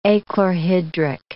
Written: achlorhydric

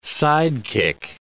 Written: sidekick

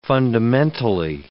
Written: fundamentally

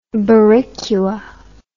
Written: boricua

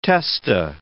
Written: testa

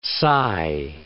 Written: sigh

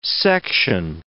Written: section

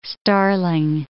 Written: starling

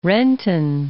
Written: Renton

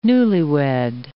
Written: newlywed

